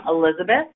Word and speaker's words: Elizabeth: So my email address is my first name, Elizabeth, spelled traditionally, E-L-I-Z-A-B-E-T-H, at Good Counsel, and counsel is spelled C-O-U-N-S-E-L, Inc., I-N-C dot org.